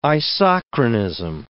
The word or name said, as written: isochronism